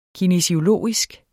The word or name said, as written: kinesiologisk